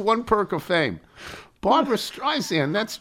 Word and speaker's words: Streisand: But even better, from a Morning Brew newsletter I received this week, my name isn't spelled with a Z. It's Streisand, like sand on the beach.